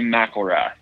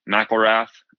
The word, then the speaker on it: McElrath: It's actually McElrath, but I do answer to either one. I've had people argue with me about it at times. And I mean, I was raised to say it McElrath. I don't jump on anybody for saying it wrong because I've heard it different ways all my life.